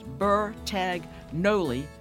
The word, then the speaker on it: Bertagnolli: Vicki's last name is spelled Bertagnolli, as if it were pronounced Bertagnoli with a Y.